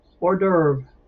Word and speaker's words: hors d'oeuvre: Hors d'oeuvre, a small savory dish, typically one serves as an appetizer. It is French in origin and pronounced with a silent H.